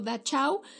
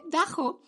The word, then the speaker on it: Dachau: Eh, que si lo leemos en español, yo lo leía como Dachau. Estando ahí, pues me corrigieron mi, obviamente, pésima pronunciación alemana y, y que se dice Dajo.